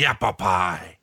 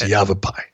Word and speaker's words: Yavapai: I love the pronunciation from Hogan. The Yappapai. – It's Yavapai.